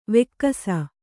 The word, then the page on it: ವೆಕ್ಕಸ: vekkasa